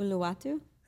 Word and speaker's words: Uluwatu: Uluwatu.